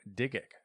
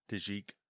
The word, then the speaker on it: DIGIC: Yeah, make it a hard J sound. Digic? – Yeah. – All right, we'll go Digic. – Or Digic. – Digic. Yeah, we're going to go with Digic. – But like chic? – Yep.